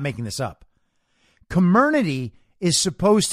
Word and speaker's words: Comirnaty: It's called Comirnaty, right? Apparently, that's the right way to pronounce the new brand name of the BioNTech Pfizer-like vaccine, right?